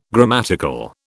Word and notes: grammatical: British English

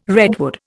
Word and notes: redwood: Indian

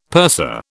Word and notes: purser: British English

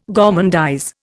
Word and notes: gormandize: Indian

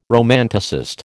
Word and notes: romanticist: American English

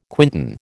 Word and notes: quintain: American English

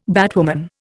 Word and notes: batwoman: Indian

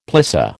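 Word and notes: plissé: American English

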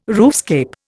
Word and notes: roofscape: Indian

